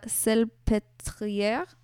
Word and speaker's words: Salpêtrière: So he studied medicine in Paris, and he did his internship at the Hospice de la Salpêtrière. I have no idea if I said that right. – That was a really good French accent, so I'm going to say you 100% said that correctly.